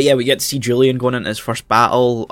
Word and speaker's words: Julian: Like, we see Julian, which, interestingly, I don't know if I've brought this up yet, in the show they pronounce it Julian. And I was like, that's kind of weird. But then I thought, there's a lot of Germanic influence on the show in terms of the Galactic Empire, and the Free Planets Alliance are, like, rebels of the Galactic Empire. So it would make sense if they pronounced it Julian, because in German, the J is pronounced as a Y.